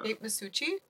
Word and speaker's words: Masucci: I don't know how to say her last name. Masuki? Makuki? From Garfunkel and Oates. It looks Italian. Kate Masuki? – Yeah. It's definitely Italian. Yeah, she... – Kate Masucci? Yeah, that's Kate Makuchi.